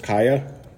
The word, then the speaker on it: Kiah: I'm with Kaya Buchanan.